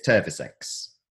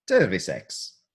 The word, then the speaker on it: Terviseks: So Estonian for cheers is Terviseks. Terviseks. – Terviseks. – As I would pronounce it.